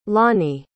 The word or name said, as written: lonnie